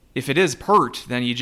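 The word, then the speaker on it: Peart: And I guess that would explain, I thought it was weird the St. Thomas guy was going Piert for, I mean, I'm assuming it's Pert. Maybe I'm just basic. I'm mainly going off of, I believe now late Rush drummer Neil Pert, which is spelled the same way.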